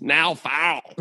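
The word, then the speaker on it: Niall: By the way, you keep pronouncing Neil Farrell's name wrong. It's now foul.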